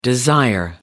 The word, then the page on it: desire: American English